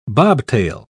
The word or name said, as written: bobtail